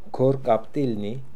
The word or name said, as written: Korkaptilnian